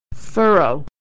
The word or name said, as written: furrow